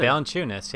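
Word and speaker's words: Valančiūnas: Then Amir Johnson and Jonas Valanciunas. Hope I'm pronouncing that right. – Probably yeah, not. – Yeah, that's right. really yeah, good. Valanciunas.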